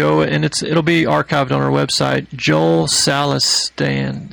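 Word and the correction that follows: Salatin: I'm sorry, I'm getting his last name wrong, but Polyface Farms, Joe Salatan, that's it, Polyface Farms in Virginia.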